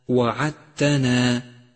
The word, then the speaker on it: وعدتنا: سابعا لاحظ الدال الرمادية في كلمة وعدتنا وعدتنا، فهي لا تلفظ لأنها مدغمة بما بعدها كما سيأتي.